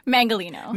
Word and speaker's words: Mangalino: Mangalino. – Mangalino. – It's pronounced how it looks like on paper.